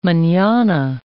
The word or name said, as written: manana